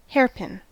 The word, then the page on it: hairpin: American English